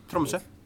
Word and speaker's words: Tromsø: Tromsø.